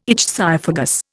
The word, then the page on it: ichthyophagous: Indian